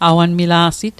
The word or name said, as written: awanmila'sit